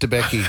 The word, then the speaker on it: Debicki: Elizabeth Debicki.